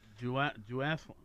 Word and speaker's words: duathlon: Then there's a duathon. Duathlon. How do you say that? – It's pronounced duathlon, and that happens simultaneously with the triathlon.